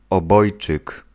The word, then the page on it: obojczyk: /ɔˈbɔjt͡ʂɨk/